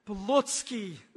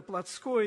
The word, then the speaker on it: плотский: Хочу оговориться, братья и сестры, правила русского языка требуют ударения в слове «плотский», но вот наше узковедомственное употребление этого слова смещает ударение на то, что мы произносим это «плотское», я придерживаюсь этого правила, это не суть важно.